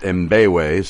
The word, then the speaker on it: Mbewe: Actually, I know his name is pronounced Mbewe since I've known him since 1995.